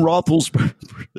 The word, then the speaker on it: Roethlisberger: And Trump calls Natalie Gulbis and says, I read in page 6 that that Ben Roethlisberger, is that pronunciation correct, Ryan? – Yeah, sure, for Trump, yeah. – Yeah. – That Ben Roethlisberger, that's funny, that Ben Roethlisberger broke up with you.